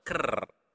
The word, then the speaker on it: ker: Okay, not kel, but ker. Okay, so remember to pronounce the R clearly.